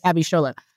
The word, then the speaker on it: Abishola: CBS show, Bob Hart's Abishola. Am I saying it right? – Yeah. Yes. – Abishola.